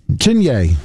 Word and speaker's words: Chiney: Is it Chiney Okawike? – Chinye.